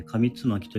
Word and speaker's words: 上巻: えー、昨日からですね、あの、時期、古事記と書いて古ことふみと呼んで、上巻と書いて紙つまきと呼ぶみたいなんですけれども、まあ、これの音読をまあ、音読の練習ですね。